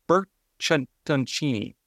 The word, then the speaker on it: Bertoncini: Bertoncini. David Bertoncini. – But it's not Bach. It's B-E-R-T-O-N-C-I-N-I. We've been saying it wrong.